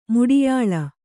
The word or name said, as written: ಮುಡಿಯಾಳ